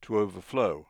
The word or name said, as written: to overflow